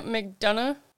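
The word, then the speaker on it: McDonagh: Tampa Bay Lightning defenseman Ryan McDonough, is that how you say his name? – It is.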